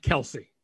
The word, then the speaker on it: Kelce: Thank you, Mason L52 says their mom has confirmed it is in fact Kelce.